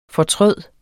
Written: fortryde

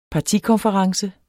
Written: partikonference

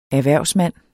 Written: erhvervsmand

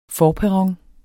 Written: forperron

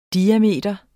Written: diameter

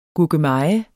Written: GURKEMEJEN

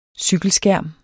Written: cykelskærm

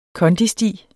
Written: kondisti

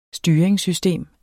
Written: Styringssystem

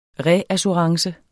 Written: reassurance

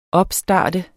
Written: opstart

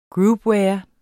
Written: groupware

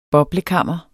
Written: boblekammer

